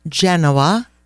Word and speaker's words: Genoa: Allora, on to Genova, or Genoa, as it's pronounced in English.